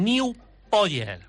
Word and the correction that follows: New Yorker: Mira, te lo pronuncio despacio para que lo digas bien. New Yorker, porque viene de New York, de donde es la revista.